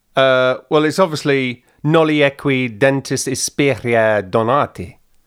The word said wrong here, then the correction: Noli equi dentes inspicere donati: I don't think that was bad. Noli equi dentes inspicere donati.